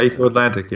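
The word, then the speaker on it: Eko Atlantic: Now, you is it called Eco Atlantic? How do you pronounce that in Lagos? – Yeah, Eco Atlantic, yeah.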